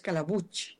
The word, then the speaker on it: Calabuig: Es muy fácil de pronunciar, es Calabuig, como si tuviera una che al final.